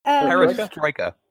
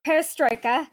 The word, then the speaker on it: Perestroika: Perestroika. – Perestroika. And were those policies really necessary, or was there a different agenda? – So this is a very big question. Period of Gorbachev reforms, so-called perestroika changes in life, in all spheres of life, economic, social, political, cultural life in our country were very deep.